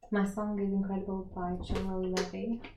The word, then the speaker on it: General Levy: I like how you said General Levy as if you weren't sure exactly how it's pronounced. – I'm not. – But you did well.